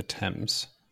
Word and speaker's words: Thames: I think minor correction, pronunciation is the Thames. – Is it the Thames? – The river you're talking about. They go with the French pronunciation, I believe.